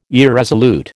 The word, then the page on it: irresolute: American English